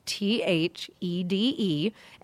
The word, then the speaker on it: Thede: So I am on all social media at Robin Thede, R O B I N T H E D E, and it is Thede, not Thede, uh, like a lot of people like to say.